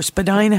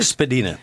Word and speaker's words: Spadina: Her topic is Historic Gardens of Spadina House, or Spadina House, but I'm pretty sure... – Spadina. You're right. – Yeah, it's pronounced Spadina House.